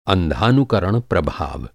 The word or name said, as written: अंधानुकरण